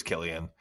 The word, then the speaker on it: Cillian: And it's just a clip of him being like, everybody mispronounces my name. It's actually Cillian. I was like, oh, duly noted. So okay, I just assumed it was Cillian, and my friend's like, no bro, it's Cillian.